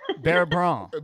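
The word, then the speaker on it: beurre blanc: Bear Blanc. It says Blanc, but you're not the C is silent.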